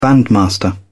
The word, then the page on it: bandmaster: British English